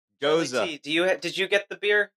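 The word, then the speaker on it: Gose: It's actually pronounced Goza. Like nose with an "a" at the end. – Still going to be ghost for me.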